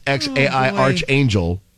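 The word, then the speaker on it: X Æ A-Xii: Which is pronounced XAI Archangel.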